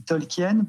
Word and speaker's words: Tolkien: je dis Tolkien par habitude, parce que c'est comme ça que Christopher, Bailey, Adam et Rachel Tolkien prononcent leur nom.